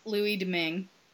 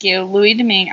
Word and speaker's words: Domingue: And the Tampa Bay Lightning traded, how do you pronounce his name? – Louis Domingue. – Louis Domingue. I always want to put an extra syllable on that.